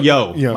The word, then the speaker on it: Yeoh: I'll find with Michelle Weo. How do you say it? – Michelle Yeoh. My bad.